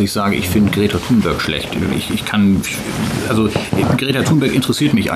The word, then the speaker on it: Thunberg: ich glaube Greta Thunberg oder Thunberg, wie sie richtig ausgesprochen wird, die ist ja im Moment gerade so, wie sie sagen, das ist ja gerade so ein Hype.